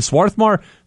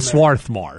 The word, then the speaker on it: Swarthmore: Now, the townies, the people who live there, don't pronounce the R. – I'm not a townie, so I can call it Swarthmore. – But all the students there, the elite students, use the R.